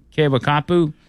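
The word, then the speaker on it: Keawakapu: I guess that's how you pronounce it. Kewakapu. – That sounds pretty good. I think he got it.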